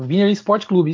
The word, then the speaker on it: Wiener Sport Club: Wiener Sport Club. Falei certo, Marcelo? Falou, é o Wiener Sport Club, isso mesmo.